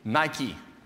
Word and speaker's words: Nike: the first prefix is E-U-U or well. The second word, I could pronounce it in the English way, Nike. That's exactly what it is, Nike.